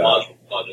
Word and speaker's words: Mod Podge: I don't know how the hell you pronounce it, but yeah, Mod Podge is correct.